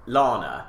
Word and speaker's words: Lahna: Because your name is Lana, but it's spelled Lahna. – Correct.